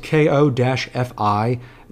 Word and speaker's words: Ko-fi: The ebook is not finished, but I am making the first part of it available for download on my Ko-fi page. It's spelled K-O-F-I. The creators call it Ko-fi. I think that's wrong, but there's a link to that page in the description of this episode.